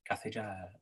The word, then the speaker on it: cathédrale: And the guy who was teaching me how to say it literally, I shit you not, said, say the English word cathedral, but in a French accent. – Cathedrale. – if you roll the R at the back of your throat, cathedrale.